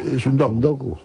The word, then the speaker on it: Suntoku: ¿ha pronunciado bien Suntoku en japonés o cómo es? – La fonética es muy parecida. – Sí, Suntoku, lo ha dicho bien. – Suntoku.